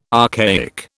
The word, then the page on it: archaic: British English